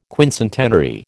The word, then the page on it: quincentenary: American English